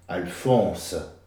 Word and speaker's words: Alphonse: Toutes les syllabes se disent. C'est-à-dire qu'à l'inverse du français, l'occitan, tout se dit. Alphonse.